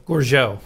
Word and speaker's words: Gourgeot: Now, with a name like Sokolowski, I really want to be sure I said it right. Say it beautifully. – Gorgeau. Yeah.